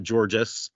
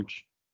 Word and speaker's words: Georges: excuse me the uh, the pronunciation guy is that correct? George Niang. It's not Georges or Georgie or something. George. – It is George.